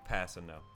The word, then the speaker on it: Kpassagnon: Yes, K-P-A-S-S-A-G-N-O-N. – Guys. – Passino. – There's no way you could say this in English, this name. It's pronounced Passino, though? – Passino.